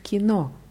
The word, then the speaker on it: кино: Bei Kino, wichtig, die Betonung ist auf O. Моя подруга хочет пойти в кино завтра вечером.